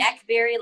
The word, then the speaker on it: Akbari: It's actually Akbari, like blackberry, but you killed the first name.